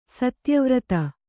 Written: ಸತ್ಯವ್ರತ

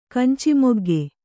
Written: ಕಂಚಿಮೊಗ್ಗೆ

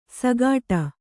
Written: ಸಗಾಟ

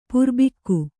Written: ಪ್ರಭಾಗ

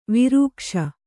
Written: ವಿರೂಕ್ಷ

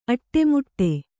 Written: ಅಡಿಮುಡಿ

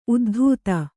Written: ಉದ್ದಿತು

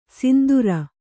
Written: ಸಿಂಧೂರಿ

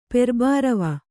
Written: ಪೆರ್ಬಾರವ

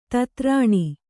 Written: ಧಾತುರೇಣು

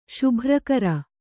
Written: ಶುಭ್ರಕರ

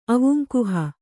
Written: ಅವುಂಕುಹ

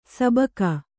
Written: ಸಿಬಿಕೆ

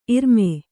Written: ಇರ್ಮೈ

